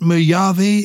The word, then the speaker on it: Mojave: Is it Mojave? Is that how you pronounce the name of the desert? – Yes, I think so, because it's from Spanish.